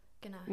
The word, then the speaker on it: ganache: Is that how you say it? – Ganache.